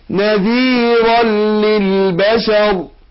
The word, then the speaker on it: نذيرا للبشر: نذيرا نذيرا عملنا ايه ادغمنا نون التنوين في اللام ادغم بغير غنى، اقول نذيرا نذيرا نون التنوين نذيرا، نون دي اللي بتتنطق مش بتتكتب ادغمناها في اللام بعدها ادغام بغير غنى،